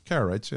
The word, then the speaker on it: Karaites: Karaites, yeah.